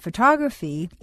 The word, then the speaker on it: photograph: The first word is stressed on the first syllable, so photograph sounds like photograph. The second word is stressed on the second syllable, photography, so the word comes out photography.